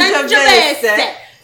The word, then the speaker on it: Sangiovese: Sangiovese.